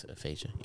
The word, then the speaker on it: aphasia: So yeah, he was diagnosed with I'm really gonna butcher this, but I think the way you say it is aphasia. – Yeah, it is. I think it's aphasia.